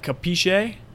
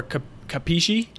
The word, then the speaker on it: capisce: Well, It's actually more like capisce or capisci. You know, because in Italian you pronounce every single letter. All those Italians where they like drop off the letter at the end, that's like Italian American.